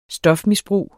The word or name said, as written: stofmisbrug